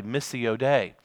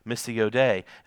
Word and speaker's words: missio Dei: And there's an old Latin phrase that's written in Latin is pronounced this way, missio Dei. And it's M-I-S-S-I-O, capital D-E-I, missio Dei.